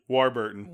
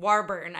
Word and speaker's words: Warburton: And I don't know that I'm going to say his last name right. Patrick Warburton? – Warburton. – Warburton. I knew I wasn't going to say that right.